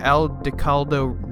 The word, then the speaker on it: Aldecaldo: He, after being kicked out of the family home, he felt betrayed by the family and sought solace with the Aldecaldo Nomad family, who are like the people who live on the...